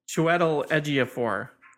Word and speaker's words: Chiwetel Ejiofor: No, it's Chiwetel Ejiofor.